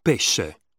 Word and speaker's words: pesce: When the combination SC is followed by the letter E or I, it is pronounced sh. Pesce. Sciare.